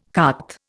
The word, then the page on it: cart: Indian